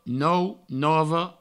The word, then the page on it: nou, nova: nou, novo